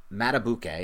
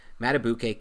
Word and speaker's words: Madubuike: And Madubuike, is that how you pronounce his name? – Yeah. – Okay, I got that wrong a few times off pod, so I'm glad I finally landed it. Madubuike, kid from Texas A&M, interior defensive lineman who Nick studied, scouted, really liked.